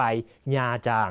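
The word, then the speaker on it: Nha Trang: ญาจางคํานี้ต้องอ่านให้ถูกนะฮะอ่านว่าญาจางน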